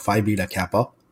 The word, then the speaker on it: Phi Beta Kappa: Phi Beta Kappa, mm-hmm. if I am pronouncing that correctly. – Yes, that's right.